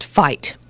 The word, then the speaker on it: phyto: But when you look at that phyto compounds and phytochemicals, and it's spelled phyto, but it's pronounced fight.